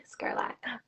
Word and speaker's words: Skerlak: You know, I think Skerlak sounds a lot like Sherlock, and some people love to put like the SH. But it's a very unique Slovenian name, actually, Skerlak.